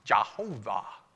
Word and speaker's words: Jehovah: And so what you would get for YHWH would become YAHOWIH, or Yahowah, or if you pronounce the Y as a J, it would become Jehovah or Jehovah, like Jehovah's Witnesses.